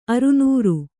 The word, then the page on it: ಅರನಾರಿ: aranāri